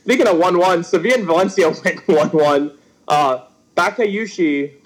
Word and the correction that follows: Batshuayi: Batshuayi, Batman, scored a late 90th minute equalizer for Valencia.